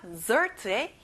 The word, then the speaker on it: dessert: 마지막 dessert에 힘을 줘보세요. Dessert. 간식, snack, snack.